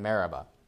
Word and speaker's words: Meribah: And so in the first case where we saw there, it says that they called the place Meribah and Massah, I believe, right? Am I saying that right? Yeah, Massah and Meribah.